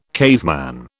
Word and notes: caveman: British English

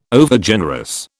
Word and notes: Overgenerous: British English

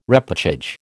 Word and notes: repêchage: American English

